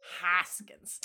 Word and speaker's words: Hoskins: Bob Hoskins. I can never say his name right. – Hoskins.